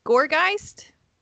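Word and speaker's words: Gourgeist: Okay, I picked Gorgeist.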